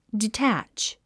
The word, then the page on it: detach: ditǽtʃ; ディタァッチ